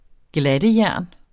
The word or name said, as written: GLATTEJERN